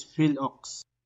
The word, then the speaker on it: PhilOx: If you guys are interested, please check out the Petron Philippine Overland Expedition and pronounce that last piece for me, the short of it. – It's Phil Ox.